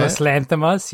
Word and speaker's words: Yorgos Lanthimos: Yorgos Lanthimos? Is that how you say it? – Yorgos Lanthimos, yeah.